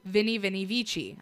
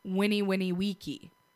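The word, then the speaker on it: veni vidi vici: Actually pronounced Winnie Winnie Wiki. V's were pronounced like W's.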